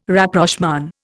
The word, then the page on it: Rapprochement: Indian